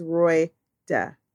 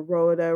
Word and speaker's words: Royda: Her name is Royda. R-O-Y-D-A. How hard is that? Royda. They call her Rhoda, Randa, Rhonda. Like, mine, this woman that known me 30-something years, and she still can't say your name? Like, even if I had to say, Roy, duh, every time I said it, I'm going to make sure that I say it right.